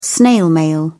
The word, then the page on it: snail mail: British English